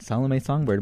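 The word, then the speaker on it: Salomé Songbird: We've got acoustic trio, like I said, Salome Songbird. Am I saying that right, everybody? – Yes. – Okay. I know there's an accent on the end, and sometimes.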